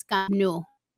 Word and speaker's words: Camp Nou: So it's Camp Nou.